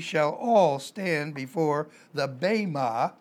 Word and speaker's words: bema: The Greek word is bema, B-E-M-A, the E is pronounced as a long A, bema, in Romans chapter 14, verse 10 through 12.